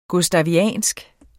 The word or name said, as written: gustaviansk